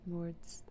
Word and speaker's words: Lorde: Lords. – Lords. – Or is that how you say her name? Lord? – Lord, yeah. – Lordy. – Yeah. – L-O-R-D-E.